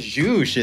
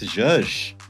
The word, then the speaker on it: zhuzh: You said it wrong. You said zhuzh. It's zhuzh.